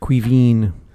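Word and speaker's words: Caoimhín: It's more of an "een" at the end, though. – Oh, fuck's sake, Cuevan.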